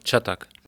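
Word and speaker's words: Çatak: Und jetzt hoffe ich, dass ich den Namen richtig ausspreche, Ilka Csatak. – Ja, Csatak.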